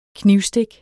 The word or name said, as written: knivstik